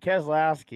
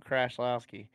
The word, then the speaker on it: Keselowski: I mean, Keselowski either. said it wrong, Keselowski.